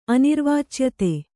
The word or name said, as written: ಅನಿರ್ವಾಚ್ಯತೆ